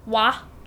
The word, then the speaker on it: Wei: It's Y, but it's got, like, a longer A sound, so it's like Wah. It's almost like Wah.